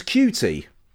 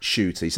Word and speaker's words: Ncuti: And they pronounced his name as Cutie. So the sort of perceived wisdom, is it's actually pronounced Ncuti.